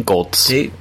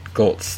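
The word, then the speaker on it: Goelz: Goltz. – Goltz, thank you.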